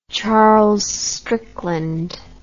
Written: Charles Strickland

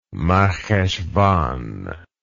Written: marcheshvan

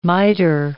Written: mitre

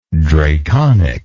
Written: draconically

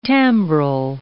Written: timbral

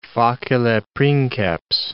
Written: facile princeps

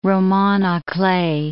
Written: romans a clef

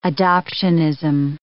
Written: adoptianism

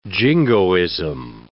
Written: jingoism

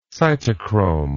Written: cytochrome

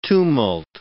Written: tumult

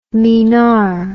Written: minar

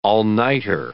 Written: all-nighter

